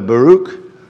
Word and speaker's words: Baruch: All right, so verse 45, the word that Jeremiah the prophet spake unto Baruch. Now, that's how you say it. I Googled it and everything, all right? I made them pronounce it to me.